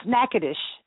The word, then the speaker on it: Natchitoches: That's Natchitoches, Louisiana.